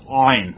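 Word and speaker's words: ayin: I can't even pronounce it well. Rhoda does. It's from the throat. Ein.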